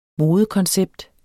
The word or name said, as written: modekoncept